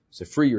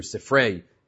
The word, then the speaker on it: Sifrei: Sifri or Sifrei, as some pronounce it.